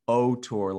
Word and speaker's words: auteur: So you gave me a task of looking into auteur, and I hope I'm saying that right, but I think it's French for author. – Yeah. Auteur is like how most people say it, but you're good.